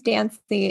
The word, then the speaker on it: Dancy: I call mine the Fancy Bot because my last name's Dancy. It rhymes with fancy.